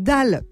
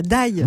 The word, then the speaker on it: dalle: Mais là, le doc, c'est « dalle », pas « die ».